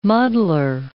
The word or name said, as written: muddler